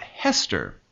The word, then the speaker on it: Hester: But the way that a Hebrew speaker would pronounce Esther wasn't Esther. That sound is hard to make, apparently. And it would come out Hester, with a H sound in front.